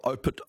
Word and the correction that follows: Opuntia stricta: I knew I'd say that wrong, opuntia stricta.